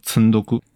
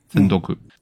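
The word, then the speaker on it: 積読: 这个词我觉得非常有意思，呃，今天在节目里可以稍微介绍一下，这个词叫积读，积累的积，读书的啊，日语里它就读作村多库，村多库。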